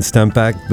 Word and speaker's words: Stempeck: So today with Brian Stempeck, the Chief Client Officer of the Trade Desk, joins us here on the AdLib podcast.